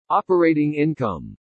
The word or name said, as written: OPERATING INCOME